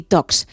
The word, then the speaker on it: Talks: Son charlas de tecnología, por eso Tech y Talks. Es que no se pronuncia la L, queremos ir de casi nativos, ¿no? Hay que pronunciarlo bien.